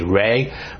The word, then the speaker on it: Ra: They also had their sun god, Ra, it's R-A, Ray. Ray, sometimes it's spelled R-A-U, but that was their main god, and the whole pantheon of gods was a sun god.